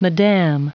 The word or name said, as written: madame